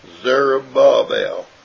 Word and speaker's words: Zerubbabel: This is the word spoken of the Lord Jehovah unto Zerubbabel, saying, and Zerubbabel, it's pretty much pronounced Zerubbabel is the way it's supposed to be pronounced.